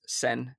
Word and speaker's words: Tseng: It's spelled T-S-E-N-G, but I assume it's pronounced Sen.